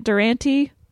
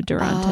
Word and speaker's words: Durante: Well, I grew up calling it Durante. – Really? – That's how my family pronounces it still. And then I graduated college. I was like, I do not like this pronunciation. So I changed it to Durante.